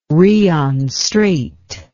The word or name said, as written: Rion Strait